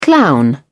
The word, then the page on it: clown: British English